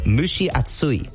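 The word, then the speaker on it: mushi atsui (蒸し暑い): Mushi is the first word. Think of like a cow saying moo, and then describing a lady, right? She, mu, she, mu, she.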